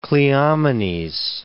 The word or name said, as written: Cleomenes